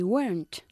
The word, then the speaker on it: weren't: No, they weren't. – No, they weren't. Y recuerda que pronunciamos weren't así. Weren't. Y no decimos weren't, ¿ok? Weren't.